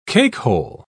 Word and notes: cakehole: British English

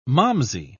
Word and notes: malmsey: British English

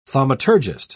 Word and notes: thaumaturgist: British English

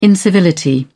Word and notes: incivility: British English

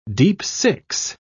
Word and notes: deep-six: British English

